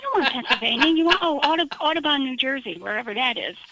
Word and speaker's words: Audubon: Audubon. Across my heart, I'm pronouncing it correctly.